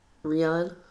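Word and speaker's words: Rian: I was about to say that they, everyone is shitting on Ryan Johnson, but you know, it's pronounced Rain. – Rion.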